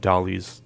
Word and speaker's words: Dalí: Dalí Museum.